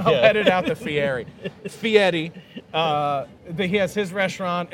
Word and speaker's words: Fieri: Guy Fieri's American Bar and Grill. – By the way, you have to pronounce, if you're going to be on Food Network, Fieri.